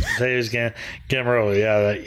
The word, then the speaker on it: Mateusz Gamrot: Armand Tsarukyan against Mateus Gamrot.